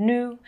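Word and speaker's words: Nous: Um, it's in collaboration with a company called Nu, but it's spelled N-U-S, but meant to represent like the French new, which means us.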